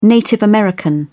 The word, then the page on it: native american: British English